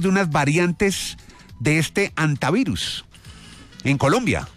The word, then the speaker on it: hantavirus: Pues antavirus, se supone que es, pero hay mucha gente que le dice también hantavirus, ¿no? Entonces sí. Yo pensaría que es antavirus porque pues el nombre está en español. Y como todo el mundo sabe, en español la H, pues no se pronuncia, ¿no?